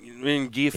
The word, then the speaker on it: Rengifo: Rengifo. – Rengifo, yes.